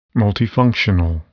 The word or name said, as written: multifunctional